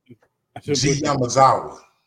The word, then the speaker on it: G Yamazawa: G Yamazawa.